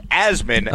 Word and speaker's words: Asman: You know, Dave Rothenberg is convinced it's Asman, which is fine, but I would like to point out that it's actually pronounced Asman, okay, as in Jasmine without the J.